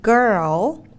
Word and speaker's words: girl: Girl là cô gái và âm cuối của nó là âm er cộng với o nên khi các bạn đọc cũng hãy đọc là girl đừng bỏ âm r đi